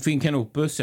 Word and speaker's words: Finn Canopus: Eller vad, ja, hur uttalar man det? – det... Finn Canopus ja.